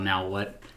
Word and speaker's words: Naohwh: There's a area called Now What, but it's spelled N A O H W H.